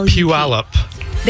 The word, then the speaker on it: Puyallup: How in the world am I supposed to pronounce this? P-U-P-U-Y-A-L-L-U-T. – Puyallup. – There you go.